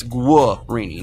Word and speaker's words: Guarini: I think it's Guarini, not Guar. Guarini. Anyway.